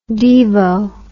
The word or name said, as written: devo